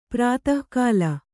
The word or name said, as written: ಪ್ರತಿಕೂಲ